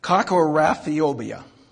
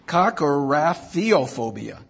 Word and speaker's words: Kakorrhaphiophobia: Ka, kora, ka kor, Cocoraphiobia. I'm going to try it again. Kakorrhaphiophobia.